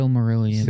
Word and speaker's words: Silmarillion: How do you say it? Silmarillion? – The Silmarillion. – Silmarillion. Mm-hmm. But like the Cosmos.